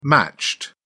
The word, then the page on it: matched: British English